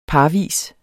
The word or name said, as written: parvis